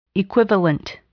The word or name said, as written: equivalent